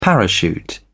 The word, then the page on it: parachute: British English